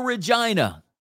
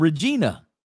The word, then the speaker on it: Regina: And then Regina, the city that rhymes with fun.